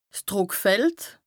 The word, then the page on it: 's Drùckfeld: Bas-Rhin; Strasbourg